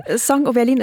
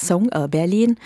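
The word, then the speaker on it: Sống ở Berlin: Song O Berlin ist ein Wortspiel, das kann man auch so aussprechen, dann heißt es sowas wie ein Lied für Berlin oder ein Lied in Berlin, aber richtig heißt es Song Berlin, was bedeutet in Berlin leben.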